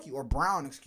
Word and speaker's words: Braun: Christian Braun, Brown. Timeout. – I don't know how to say that. I hate that boy's name. – It's spelled Braun, damn it. And he pronounces it Brown.